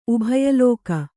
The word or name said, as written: ಉಭಯಲೋಕ